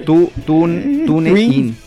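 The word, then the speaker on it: TuneIn: Se escribe T-U-N. – Tin, hombre. – Tune in. – Muy bien, ahí está, ya está.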